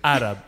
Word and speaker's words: Arab: when they got bought out with the A-Rap money. – Yo, chill with that. Chill with that pronunciation there. – With the Middle Eastern money. – Arab, not A-Rap. Come on. Not A-Rab.